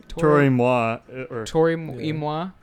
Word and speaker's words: Toro y Moi: Okay, in Spanish, do you pronounce the Y-E? Do you say E? – Yeah. – Okay. Yeah, so it's Toro y Moi or something like that.